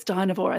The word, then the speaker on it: Dynevor: this casting of uh, Daphne Dynevor. I'm sorry if that's the wrong pronunciation. I love it. It's Dynevor. I think carnivore.